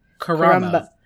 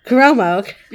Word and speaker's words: Karamo: Well, if anyone doesn't contribute something, it's Karamo. Wait, what's his name? Kuomo. – Karamo. – Karamo. – No, you said it right the first time.